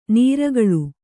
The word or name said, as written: ನಿರ್ಗಾಳಿ